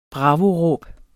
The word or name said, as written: bravo